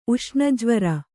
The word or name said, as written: ಉಷ್ಣಜ್ವರ